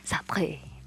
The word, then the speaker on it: Sartre: Es El existencialismo es humanismo de Jean-Paul Sartre. Las personas que hablan francés, por favor, no me corrijan, ya sé que lo dije mal. – Ya sé que no pronuncié bien. Sartre, perdón. Pero bueno, eh, pues igual si me permites leerla.